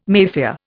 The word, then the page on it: Mafia: Indian